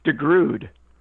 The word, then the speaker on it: DeGrood: Am I pronouncing your name right? – Pretty close. DeGrood.